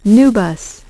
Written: NuBus

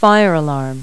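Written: fire alarm

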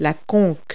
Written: concha

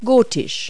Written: gothic

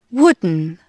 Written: wooden

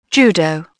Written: judo

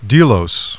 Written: DELOS

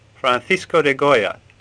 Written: Goya

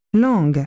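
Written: tongue

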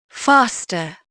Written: faster

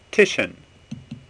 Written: Titian

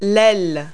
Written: wing

